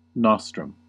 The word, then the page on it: nostrum: American English